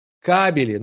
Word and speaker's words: кабели: А я сказал кобеля? Блин, ударение. Кабели.